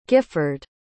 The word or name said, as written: guifford